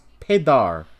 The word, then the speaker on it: peidar: Fart in Portuguese is pronounced pedar. – Pedar. – Pedar. Or pedar. – P-E-I-D-A-R.